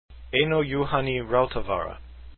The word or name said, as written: rautavaara